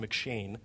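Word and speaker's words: McCheyne: It's spelled kind of funny. There's an M apostrophe C-H-E-Y-N-E, but it's pronounced McShane.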